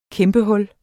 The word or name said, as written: kæmpehul